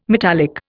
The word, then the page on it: metallic: Indian